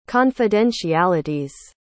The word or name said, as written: confidentialities